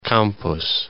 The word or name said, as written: Campos